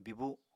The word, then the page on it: bibu: Apatani